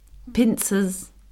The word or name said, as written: pincers